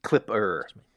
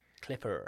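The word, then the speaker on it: Klipper: Is that Clippo, did you say? – Clipper. – Clipper. – Clipper. Sorry, notes, yeah, and spelled with a K, I believe.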